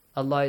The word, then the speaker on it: الله: the word Allah is pronounced with an a sound بالله وَأَخْلَصُوا دِينَهُمْ أَخْلَصُوا خَا has a heavy sound.